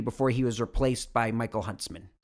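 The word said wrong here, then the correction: Michiel Huisman: It's pronounced Mikael Huseman.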